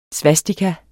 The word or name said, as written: svastika